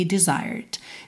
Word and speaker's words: desired: Lembra que o E não é pronunciado, então você não fala desired, desire, né? Bota o R enrolado da porta com a perna esquerda e já fecha com D, desired.